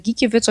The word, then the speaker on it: Gikiewicz: Und da hat er dann irgendwie gesagt, Gikiewicz.